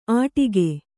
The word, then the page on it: ಆಟಿಗೆ: Kannada